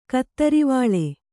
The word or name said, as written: ಕತ್ತರಿವಾಳೆ